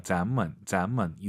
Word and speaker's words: 咱们: 네, 자몽이 아니에요. 咱们 이성경성.